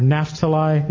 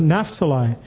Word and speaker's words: Naphtali: And Jesus fulfilled a proper prophecy in this area of Zebulun and Naphtali, or Naphtali, it could be pronounced either way, which was spoken by Isaiah. Isaiah 9:1-2 said, "The land of Zebulun, the land of Naphtali, The people who walked in darkness have seen a great light.